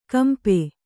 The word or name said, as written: ಕಂಪ